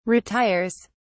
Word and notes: retires: British English

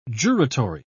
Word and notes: juratory: British English

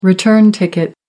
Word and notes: return ticket: American English

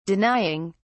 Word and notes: denying: British English

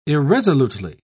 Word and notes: irresolutely: British English

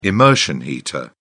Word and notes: immersion heater: British English

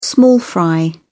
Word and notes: small fry: British English

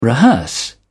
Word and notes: rehearse: British English

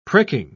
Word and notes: pricking: British English

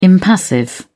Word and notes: impassive: British English

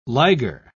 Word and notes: liger: British English

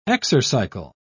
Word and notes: exercycle: British English

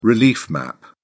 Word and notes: relief map: British English

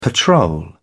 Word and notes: patrol: British English

